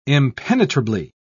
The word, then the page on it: impenetrably: British English